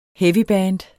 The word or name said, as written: heavyband